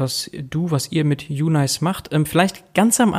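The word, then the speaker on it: uNAICE: Da wird nämlich geschrieben kleines U, großes N und dann A-I-C-E, ausgesprochen UNICE.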